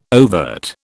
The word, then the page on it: overt: British English